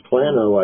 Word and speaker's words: Plano: Plano, it's Plano, I think, is how it's pronounced.